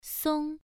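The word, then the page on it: song: tone 1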